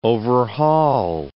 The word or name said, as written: overhaul